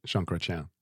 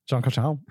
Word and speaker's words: Jean Chrétien: Jean Chatanhal, the one I can't say his name. – Jean Chrétien. – Jean Chrétien.